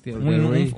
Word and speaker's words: Thierry: Thierry. Un, francés. ¿Ya? Es que así se pronuncia.